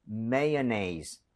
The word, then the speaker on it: mayonnaise: MAYONNAISE. 장난이 아닙니다. 그렇기 때문에 원어민들도 이걸 잘 쓰지 않죠. 이 스펠링 자체를. 읽으면 mayonnaise, mayonnaise 해서 마요도 아니라 mayonnaise가 맞아요.